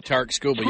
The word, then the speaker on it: Skubal: Tarik Skubal, yeah.